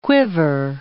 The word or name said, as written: quiver